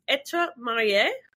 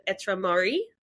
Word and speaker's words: Etre Marie: It's Etre Marie, or as a lot of Aussies pronounce it, Etre Marie, which means to be married in French.